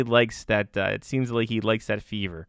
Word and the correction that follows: fervor: He likes that that fervor, rather, if we pronounce it properly in English.